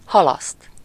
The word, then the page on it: retarder: [ʁə.taʁ.de]